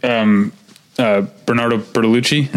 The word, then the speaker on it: Bernardo Bertolucci: Yeah, so an interview with Bernardo Bertolucci, am I saying that right? – That's right.